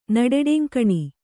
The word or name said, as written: ನಡೆಡೆಂಕಣಿ